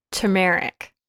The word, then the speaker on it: turmeric: Like reaffirmed my mispronunciation of turmeric. Did I say it right? – Turmeric. – Turmeric. See, I'm trying to like rhyme it with like America or something in my head so I don't say it how America.